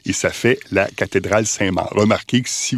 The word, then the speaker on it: Saint-Marc: il faut pas dire la basilique Saint-Marc, mais il faut dire la basilique Saint-Marc. Faut apparemment pas prononcer le C final,